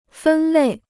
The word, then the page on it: 分类: fēn lèi